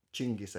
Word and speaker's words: Chinggis: And is that um, how you say it? Jinkis. – Chingis.